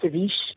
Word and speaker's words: ceviche: All right, so this one's spelled C-E-V-I-C-H-E. – Yeah, and you got it right, ceviche.